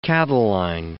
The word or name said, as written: Catiline